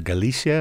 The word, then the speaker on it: Galicia: And Mairead, here's a tune that features you on the violin called The Coast of Galicia. Am I pronouncing that right? – Yes.